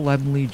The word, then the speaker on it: Laemmle: See, even now, I almost called him Carl Lemmel Jr. I don't know why I do that, but Yes! Carl Laemmle Jr. For all the times I've said it wrong, I'm saying it right now.